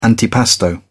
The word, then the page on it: antipasto: British English